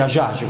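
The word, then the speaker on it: Hadjadj: Si scrive Adiadi, ma si dice Ajage, così o meno lo dicono nei telegiornali, mi sono abituato a dire così.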